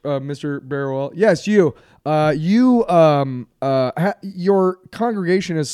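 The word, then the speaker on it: Barruel: Can you imagine Augustin Berruel, I believe is how you say his name. – Yeah, it's Berruel, but I'm going to just say Augustin.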